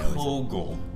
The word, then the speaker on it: Kogel: My boy, CJ, I'm going to butcher your last name. I don't know why. – Kogel. – Kogel, is that how you say it? – That's Kogel? – German.